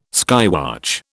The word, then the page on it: skywatch: British English